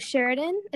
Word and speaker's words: Sheridan: So for everyone tuning in, I'm very excited to be talking with Sheridan.